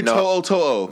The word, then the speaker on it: To'o To'o: You got to pronounce his last name. – Henry To'o To'o. – There you go.